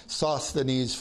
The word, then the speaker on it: Sosthenes: Then all the Greeks took Sosthenes, and I looked this up and somebody said the proper way to pronounce Sosthenes is Sosthenes. And I can't get myself to say it that way because I've heard it Sosthenes for so long.